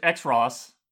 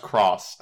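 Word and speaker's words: Xros: What's X-Ross? – Fusion. – Oh, Fusion, that's right. – And it's just pronounced Cross. – I'm saying X-Ross.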